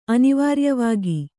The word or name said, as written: ಅನಿವಾರ್ಯವಾಗಿ